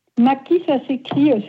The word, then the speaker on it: Madchi: Mati, ça s'écrit, c'est un nom d'origine italienne. – Oui. – M-A-D-C-H-I. – D'accord. Et ça se prononce Mati? – Ma belle famille a décidé que ça se prononçait Mati.